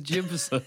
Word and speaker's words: Jimson: If it makes you feel any better, I still say Jimson's name. Jackson's name. – No, Adam, it is Jimson. – They pronounce it incorrect. – I'm Jimson Bailey. – B-A-I-I-Y.